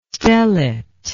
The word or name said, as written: stellate